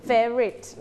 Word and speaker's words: favorite: ทำได้ดีมากเลยนะคะสำหรับการถามและการตอบแสดงว่ามีคำศัพท์คำหนึ่งที่ค่อนข้างยากคือคำว่า favorite ใช่ไหมคะเวลาออกเสียงบางครั้งนักเรียนจะสับสนบางทีพูดออกมาเป็นคำที่คุ้นเคยเช่น family แบบนี้นะคะจริงๆคำนี้คือ favorite นะคะเจอหลายๆห้องนะคะไม่ใช่แค่ห้องนักเรียนต้นทางนะคะห้องอื่นๆที่ สอนก็เจอปัญหาเดียวกันเลยเพราะฉะนั้นนักเรียนต้องฟังเยอะๆนะคะแล้วก็เรียนแบบเสียงให้บ่อยๆพยายามเก็บเสียงไทยให้ดี favorite everyone say favorite Very good.